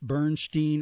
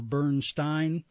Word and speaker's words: Bernstein: And I looked this up because I really get confused, Bernstein or Bernstein. And it seems that he pronounced it both ways, depending on how old he was. Younger, he did Steen. And then when he got older, it was Stein.